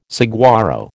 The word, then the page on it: saguaro: American English